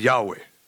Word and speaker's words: Yahweh: And so when Moses asks God for his name, he gives him his name, and it's spelled YHWH, which if you pronounce it, is Yahweh.